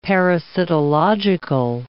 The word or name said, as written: parasitological